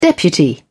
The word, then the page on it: deputy: British English